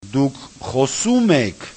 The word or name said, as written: dukkhosumek